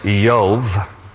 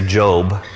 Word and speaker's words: Job: In Hebrew, his name is pronounced Yov. We would say Job. And the whole book of name means persecuted one. In Hebrew, his name is pronounced Yov.